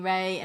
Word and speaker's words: Reay: When people say, how do you pronounce Reay? Well, it might be R-E-A-Y, but it's like rays of sunshine.